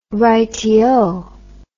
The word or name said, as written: rightio